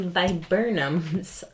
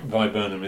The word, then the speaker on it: Viburnum: If anyone recalls me mentioning the viburnums, I'm not even sure if I'm saying that right. – Viburnum is right, I think.